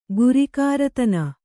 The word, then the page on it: ಗುರಿಕಾರತನ: gurikāratana